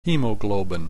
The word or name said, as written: hemoglobin